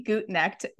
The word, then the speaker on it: Gutknecht: Super excited to have Danny Gutknecht with me here today.